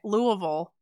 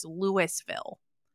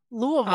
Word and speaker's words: Louisville: Louisville is Kentucky, where I have family, and I would be in big trouble if I didn't pronounce it correctly. In Colorado, a town with the same spelling is Louisville. Oh, that's funny. Uh, but anyway, yeah, yeah, and you have to pronounce them both correctly. – Louisville, obviously, you're you're basically eating it into the back of your throat.